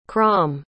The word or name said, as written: crom